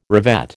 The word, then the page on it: revet: American English